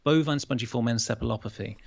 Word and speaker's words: bovine spongiform encephalopathy: It was bovine spongiform encephalopathy.